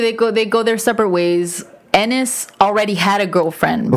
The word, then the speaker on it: Ennis: Yeah, and Ernest and Jack kind of go their separate ways after that, because Ennis Delmar. Ennis. – Heath Ledger. – I remember that's how you say it. Ennis.